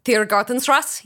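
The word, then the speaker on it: Tiergartenstraße: It comes from the street address of the program's coordinating office in Berlin, Tiergartenstrasse. Did I pronounce that okay? – Tiergartenstrasse, yes.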